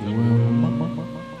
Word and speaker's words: Wumme: W-U-M-M-E. With the pronunciation of Womb.